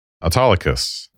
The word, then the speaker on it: Autolycus: But in any case, you know, so now going into scene 4.3, we're going to have to try to make sense of Autolycus.